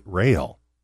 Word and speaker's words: Roehl: So my real name is Gary Roehl, but it's R O E H L, very difficult to pronounce.